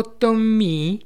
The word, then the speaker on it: Томми: «Я никуда не могу идти без моего Томми», — говорила она, произнося его имя на французский лад с ударением на последнем слоге.